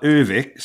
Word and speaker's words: Övik: But even Swedes call it Övik, so I think that's Övik, what I'll do as well.